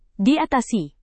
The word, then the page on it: diatasi: Indonesian